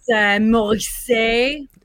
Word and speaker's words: Morissette: Got art by Morissette. Did I pronounce that correctly? – I mispronounced it for, I think, 7 of our 10-year collaboration. It is Morissette. – Of course, it's in French.